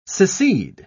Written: secede

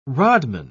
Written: rodman

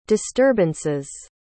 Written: disturbances

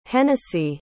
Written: Hennessy

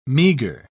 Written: meager

